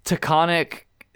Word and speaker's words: Taconic: um, this this Taconic, did I say that right? Taconic Crest Trail. Yep.